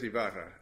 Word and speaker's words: Ibarra: Yes, I-B-A-R-R, not I-V. – I said Ibarra. I'm sorry, I'm pronouncing it differently. But it is Ibarra is the case, I-B-A-R-R-A.